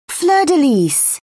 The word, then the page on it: fleur-de-lis: British English